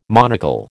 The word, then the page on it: monocle: American English